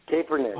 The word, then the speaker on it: Kaepernick: How do you say that? – Kaepernick. – It's tough name. – Colin Kaepernick. – Colin Kaepernick.